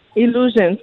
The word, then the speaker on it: Illusions: ¿Es Illusion o Illusions? Illusions, como ilusiones.